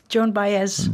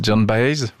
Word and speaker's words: Joan Baez: Joan Baez. – Joan Baez, vous le prononcez mieux que moi.